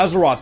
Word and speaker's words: Azeroth: Am I pronouncing that right? Yeah, am I? Okay, good. Yeah, Azeroth.